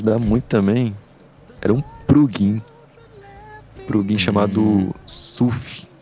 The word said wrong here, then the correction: Soothe: Acho que é tipo, escreve. Vou, vou falar como se escreve. Sute. Escreve. – Sute. É Sute? É. – S-O-O-T-H-E.